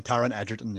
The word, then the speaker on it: Taron Egerton: Taron Egerton. – You were closer the first time. Taron Egerton, yeah.